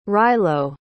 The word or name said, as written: rilo